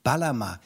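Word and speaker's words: Bağlama: Da spricht man das G ja auch nicht aus. – Baklama.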